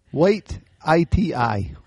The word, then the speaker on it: Waititi: Taika Waititi? – Waititi? Wait, I-T-I.